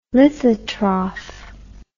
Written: lithotroph

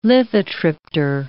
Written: lithotriptor

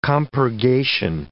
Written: compurgation

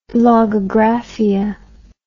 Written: logagraphia

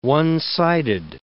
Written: one-sided

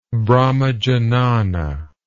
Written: brahmajnana

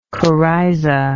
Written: coryza

